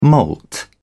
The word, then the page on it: moult: British English